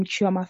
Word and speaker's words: Chioma: So my name, Chioma Fanawopo, sometimes there's a dot.